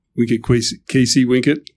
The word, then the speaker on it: Vincit qui se vincit: So you know, going back to my school motto, "Wink it, Kisi, Wink it," the the modern Latin pronunciation of um, Vincit qui se vincit, which was the classic way of pronouncing it.